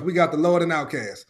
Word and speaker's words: Outkast: Pronounced Outkast. O-U-T-K-A-S-T.